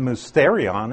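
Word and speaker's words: mysterion (μυστήριον): We use that word, it's pronounced mysterion in Greek, but that's exactly where our word mystery comes from.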